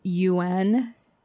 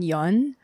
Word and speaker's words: Yeun: Stephen Yuen. It's Stephen Yun. – Yeah. – But in English, the it's actor? Spelled Y-E-U-N.